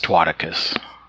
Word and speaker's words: Twaticus: If you have ever been on the popular site Chaturbate, you know this muscular, blue-eyed, big-dicked, and down-to-earth man as Twaticus. Twaticus, how do you say that? That's how I've been saying it. – That's how you say it.